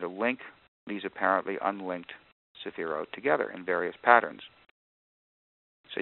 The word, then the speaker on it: sephirot: Each one is called a sephira, spelled S-E-P-H-I-R-A-H. The plural of that is sephirot, and it has an O-T-H on the end. S-E-P-H-I-R-O-T-H, and it's pronounced that way. The accent is on the last syllable, sephira and sephirot.